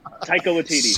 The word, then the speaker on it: Taika Waititi: I can never say it. Taiki? – Jason. – No. – Taika Waititi. – Smith. Taika Waititi.